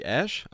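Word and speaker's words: Æ: und man muss dazu sagen, ausgesprochen wird dieses AE anscheinend wie Ash, also X-Ash A-12 würde dieses Kind dann heißen, wobei aber Grimes anscheinend auch wieder gesagt hat, nee, das wird I oder I ausgesprochen, also so, dass dann irgendwie I oder so rauskommt.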